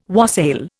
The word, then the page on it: wassail: Indian